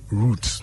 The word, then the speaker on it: route: So we say root. – It's root. – Yes. – Just the same as you say the root of the tree.